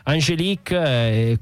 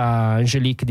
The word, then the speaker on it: Angélique: Angélique, qui c'è un cambio di nome da quello che era la pronuncia inglese a quella francese, quindi una trasformazione anche imposta dalla lingua colonizzatrice, dalla lingua bianca.